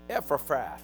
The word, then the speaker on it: Epaphras: As you also learn for, how do you say his name? Epaphras.